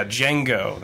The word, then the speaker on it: Django: "The D is silent, hillbilly." nice, because they said Django. well, I'm glad I brought that up. So yeah, Django.